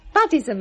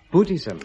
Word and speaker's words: Buddhism: With that Buddhism of yours. – Buddhism, Mum. I told you, it's pronounced Buddhism.